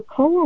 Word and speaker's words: koa: Now, you also talk about this, I don't know if I'm pronouncing it right, koa wood, which is legendary. – Koa.